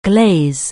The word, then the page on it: glaze: anglais